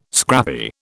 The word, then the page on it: scrappy: British English